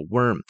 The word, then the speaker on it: worm: 好，这是一个毛毛虫，毛毛虫叫做 Caterpillar，然后蚯蚓是刚才的那个地球 E-A-R-T-H Earth，然后后面再来一个 Worm，这个字的发音要稍微小心一下，因为 O-R 是一起念的，跟我们的温暖或者是那个有点热的那个 Worm 会稍微有点不一样，那个是 W-A-R-M，这个是 W-O-R-M，它的拼法是有点不一样，但念法当然也是有点不一样。